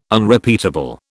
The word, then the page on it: Unrepeatable: British English